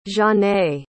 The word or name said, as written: zhane